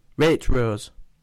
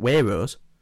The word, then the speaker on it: Waitrose: You're copying me, but you're still saying it wrong. Waitrose. – It's Waitrose. – Wait, stop it. – Hey, that's how you fucking say it. – Waitrose. Waitrose. It's got a T in it. Not Waitrose.